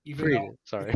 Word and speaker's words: Fried: First of all, let me just tell you my name's pronounced Fried. – Fried, sorry. – If you see it on the menu, you probably think it's called Fried. – Sorry. – It's Fried, it's no problem.